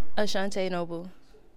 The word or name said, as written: Ashantay Noble